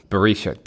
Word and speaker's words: Barisha: And we're joined by assistant producer, Petrit Berkshire. Petrit, how are you, sir? – I'm very well. Thank you, Barishka, Simon. Not Berkshire. I can never say your name. It's Barishka. – Barisha. – Barisha.